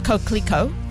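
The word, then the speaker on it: Coquelicot: It is pronounced Coquelico.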